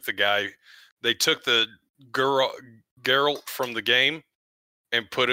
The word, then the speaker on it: Geralt: It's Geralt, right? That's how you pronounce it. – Geralt.